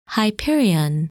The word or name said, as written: Hyperion